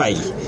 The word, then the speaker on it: Feige: Ya escuché cómo se pronuncia. Feige.